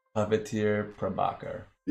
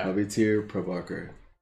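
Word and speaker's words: Pavitr Prabhakar: Puppeteer Prabhakar. – Yeah. That's basically how they say it. – Yeah. – Puppeteer Prabhakar.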